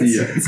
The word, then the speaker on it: Precilla: Precilla, you pronounce that right? – Precilla, Spanish.